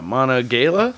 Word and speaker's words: Monongahela: I don't know pronounce that word. – What's? Let me see it. M O N O N G A H E L A. Where is it? Mono, Monon. Monongahela? Yeah, Monongahela? Monongahela River.